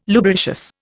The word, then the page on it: lubricious: Indian